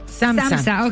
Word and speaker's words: SAMHSA: SAMHSA. – ¿Cómo? – SAMHSA. ¿Cómo se dice? – SAMHSA, ok.